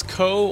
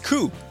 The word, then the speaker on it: Khoe: You know, there's often two different ways. It's Khoe or Khoe, and neither of them are correct. It's Khoe, like shoe with a K.